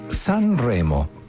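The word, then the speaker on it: Sun Remo: Y el proyecto que vamos a escuchar ahora se llama San Remo, pero es así, S-U-N, San Remo se escribe.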